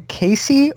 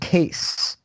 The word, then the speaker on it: Case: Is his first name Casey or just Case? Because I know it's spelled C-A-S-E. But that's not pronounced Casey? – It's Case. If it's Casey, he hasn't corrected anybody because all the NFL announcers have been saying Case.